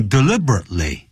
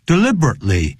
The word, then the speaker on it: deliberately: 那个时候就等于 deliberately. D-E-L-I-B-E-R-A-T-E-L-Y. Deliberately. 我们中国人常常会念 deliberately, 不是 tlay. 那个 deliberate, 停顿一下 ,lay. Deliberately.